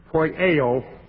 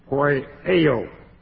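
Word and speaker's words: Poieo (ποιέω): It's the Greek word poieo, P-O-I, long E, long O. Poieo.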